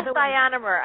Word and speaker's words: ionomer: And why is this glass ionomer, however you say it? I can't even say it. So I'll let you. – Glass ionomer. I'm going to spell it one more time. I-O-N-O-M-E-R.